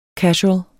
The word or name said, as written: casual